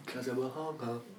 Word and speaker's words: Casablanca: Oh man, I want to say something like Casablanca, like something old and... – Mm. How do you like them? – It's not as old as that. – Okay, it's not as old as Casablanca. – Casablanca.